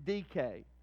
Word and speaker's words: Dike: In the Greek, her name was spelled D-I-K-E. You would pronounce it D-K.